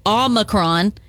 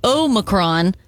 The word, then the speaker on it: Omicron: So both Omicron and Omicron are fine. The Oxford Dictionary includes Omicron. And in classical Greek, it was pronounced with the second syllable sounding like me.